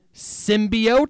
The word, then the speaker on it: symbiote: Someone tell that one lady how to actually pronounce the effing word symbiote.